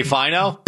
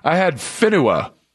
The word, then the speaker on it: Finau: Did you say Finau? – Butchered it. – Finau? – I had it backwards. I had Finua. Because I had the U, because it's spelled F-I-N-A-U, so I had it F-I-N-U-A written down in my notes.